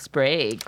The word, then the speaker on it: Sprague: Sprague? That's how you pronounce it? – You got it. – Nice. – Sprague. – Prague with an S.